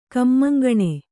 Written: ಕಮ್ಮಂಗಣೆ

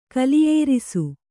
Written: ಕಲಿಯೇರಿಸು

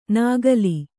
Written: ನಕಲಿ

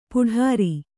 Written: ಪೌಡರು

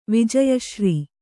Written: ವಿಜಯಶ್ರೀ

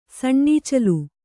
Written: ಸಣ್ಣೀಚಲು

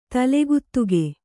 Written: ತಲೆಗುತ್ತುಗೆ

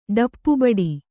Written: ಡಪ್ಪುಬಡಿ

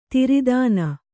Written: ದೊರೆತನ

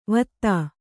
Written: ವತ್ತಾ

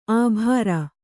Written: ಅಬರು

